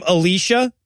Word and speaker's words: Elijah: The twins are named Elisha and Elijah, except they pronounce the girl's name Alicia, but they spell it Elijah.